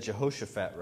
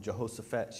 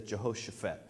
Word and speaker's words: Jehoshaphat: She even said Jehoshaphat, right? I was saying it wrong. I said Jehoshaphat. She says Jehoshaphat.